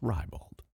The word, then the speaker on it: ribald: Oh, ribald. The word is ribald.